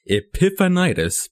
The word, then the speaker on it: Epiphanitis: Hi, thank you for tuning in for the first Epiphanitis podcast.